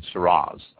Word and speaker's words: Syrah: A lot of people are familiar with Australian Syrah, especially here in the United States now. A lot of people come in and pronounce the Syrah with a Z. Syrah, the old Syrah grape, is spelled S-Y-R-A-H.